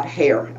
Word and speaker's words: Heyer: I cannot tell you how much I love Georgette Heyer. Uh, Hare, I know that's how you pronounce it.